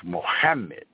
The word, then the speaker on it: Muhammad: But the Muhammad is a meme and it's a ha and then a meme. You know what I mean? That's how you pronounce Muhammad in the Arabic.